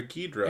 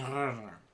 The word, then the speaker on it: Ghidorah: Or Ghidra. – It's pronounced King Ghidorah.